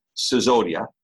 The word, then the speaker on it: Sisodia: Sisodia.